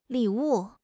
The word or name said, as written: li3 wu4